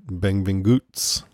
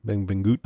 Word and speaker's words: Benvinguts: This week we're covering Cobra Kai Season 6 episode, Benvinguts a Barcelona. Pardon my pronunciation. – That's better than I could have done. – Pretty close. I went online and got a pronunciation. They said Benvinguts, like that. Benvinguts.